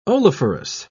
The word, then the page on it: oleiferous: British English